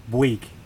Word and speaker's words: Bouygues: So tell me about, and help me pronounce it, Bouygues? – Bouygues.